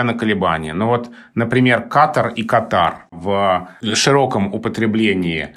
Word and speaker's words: Катар: После того, как в этой стране было принято решение, что пройдет чемпионат мира по футболу, и это название стали употреблять чаще, с таким ударением «Катар», оно как-то так вот больше закрепилось в речи, и, собственно, словарь имен собственных отдает предпочтение ударению «Катар». И сейчас тоже можно часто встретить ударение «Катар».